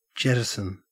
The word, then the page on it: jettison: Australian English